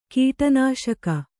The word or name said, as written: ಕೀಟನಾಶಕ